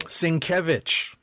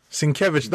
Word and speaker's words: Sienkiewicz: This mid-credit scene is at, apparently, Sienkiewicz Psychiatric Hospital. – Sienkiewicz. – Yes. – Sienkiewicz.